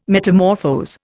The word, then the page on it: metamorphose: Indian